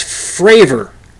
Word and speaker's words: Fravor: Found out what it's Fravor.